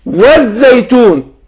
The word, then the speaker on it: الزيتون: طيب والزيتون برضو اللام اللي في الزيتون نفس اللام اللي في التين، لام شمسية زي ما قول والشمس، يبقى نطقتش اللام ادغمت في الحرف الذي بعدها.